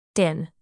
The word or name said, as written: din